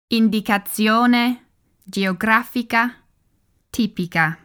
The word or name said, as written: Indicazione Geografica Tipica